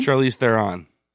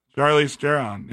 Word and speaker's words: Charlize Theron: Is that how you pronounce her name? – Charlize Theron. – Charlize Theron. Yeah.